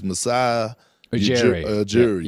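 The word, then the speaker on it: Masai Ujiri: I always have problems pronouncing his name, but it's Masai Ujiri.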